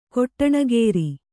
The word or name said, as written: ಕೊಟ್ಟಣಗೇರಿ